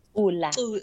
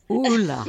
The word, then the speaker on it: Kula: Is it Kula or Kula? – Kula. – Kula. – Yes.